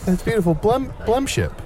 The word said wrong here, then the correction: blendship: Am I saying it right? Blemship. – Blendship. – B- Blend.